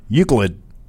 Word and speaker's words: Euclid: Euclid.